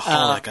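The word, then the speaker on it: xxxHOLiC: I think it's just called Holic. I think all the X's are silent.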